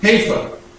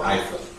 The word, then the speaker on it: Haifa: It's just a little south of Haifa, okay, or Haifa, okay, whichever the locals say it.